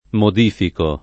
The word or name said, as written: modificare